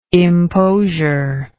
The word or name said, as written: imposure